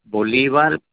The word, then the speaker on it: Bolivar: Okay, first name is Bolivar, B like a boy, O-L-I-V-A-R, B like Victor, V-A-R, and the last name is Figueroa, F-I-G-U-E-R-O-A.